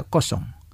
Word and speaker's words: kosong: Sin embargo, en conversaciones sobre números, como números de habitación o números de teléfono, el número cero a menudo se pronuncia kosong.